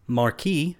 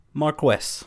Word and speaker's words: marquess: There are two pronunciations given. – Share them, please. – Marquis, which I think most people are familiar with, and Marquess. Both wholly accurate pronunciations. Of the European title of nobility.